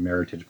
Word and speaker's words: Meritage: It's a 2014 Meritage blend. – Rhymes with heritage.